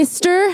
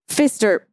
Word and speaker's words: Pfister: The Pfister. How do I pronounce it? Pfister.